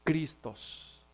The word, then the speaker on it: Christos: En el griego, la palabra ungido se pronuncia Christos, de donde viene nuestro término Cristo.